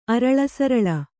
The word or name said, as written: ಅರಲಸರಲ